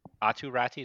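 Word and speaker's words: Aatu Räty: Atu Ratti, is that how you say it? – Yep.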